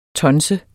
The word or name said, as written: tonser